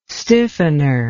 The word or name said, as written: stiffener